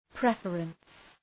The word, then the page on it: preference: ‘prefərəns